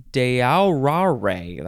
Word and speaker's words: Deaurare: This is a Spanish word from the verb dorar, which means to gild, G-I-L-D, from the Latin verb, how do you say this word? Deaurare? There's three vowels in a row. D-E-A-U-R-A-R-E. Deaurare.